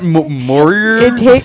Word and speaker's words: Moria: The Mines of Moria. – Isn't that Moria? – I'm sorry, Moria. – Moria. Moria. – Spell that for us. – M-O-R-I-A.